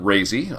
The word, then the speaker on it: Razy: Our Starfarer pilot, Razy, informed me that his name is, I mentioned this, is pronounced like crazy.